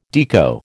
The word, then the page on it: Deco: American English